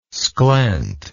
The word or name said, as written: sclent